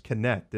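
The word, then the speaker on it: Knecht: It's pronounced Connect, isn't it? Dalton Connect. – Dalton Connect, yes.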